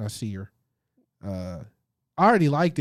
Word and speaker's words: Nasir: Nasir.